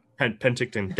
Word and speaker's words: Penticton: And then it goes into the I'm going to probably butcher this word, the Penticton Channel, which is a couple miles long. Penticton, thank you.